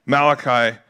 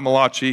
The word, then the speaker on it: Malachi: In the next 300 years, Malachi shows up. Maybe you're watching FIFA and you're like, how do you pronounce it? It's not Malachi. He's not an Italian soccer player. He's a prophet, Malachi, son or daughter.